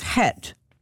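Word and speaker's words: chet (חית): this letter right here is chet, and this, of course, it begins with that ha sound, ha, chet.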